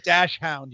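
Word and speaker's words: Dachshund: And it's pronounced Dash Hound, you ingrate.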